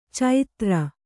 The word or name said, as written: ಚೈತ್ರ